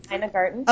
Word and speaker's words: Ina Garten: Bobby Flay, chef of Mesa Grill, Sandra Boynton, Ina Garten, I don't know if I'm pronouncing her. – Yep, Ina Garten.